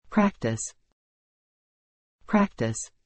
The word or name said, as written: practice